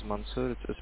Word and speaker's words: Mansur: My name is Mansoor. Spell it as M-A-N-S-U-R.